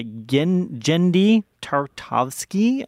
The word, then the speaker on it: Genndy Tartakovsky: It's directed by Genndy Tartakovsky.